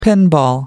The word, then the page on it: pinball: American English